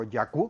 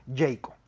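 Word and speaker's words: Jaecoo: Por mi parte, pues conociendo todas las novedades de Omoda y la nueva marca que estará llegando a México, Jaco o Yacu, como lo pronuncian aquí. Por otro lado, ajá, Yacu, así le le dicen aquí, pero el nombre oficial en México pues va a ser Jayco.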